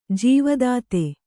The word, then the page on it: ಜೀವದಾತ: jīva dāta; kannada